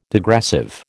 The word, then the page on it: degressive: American English